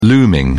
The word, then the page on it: looming: British English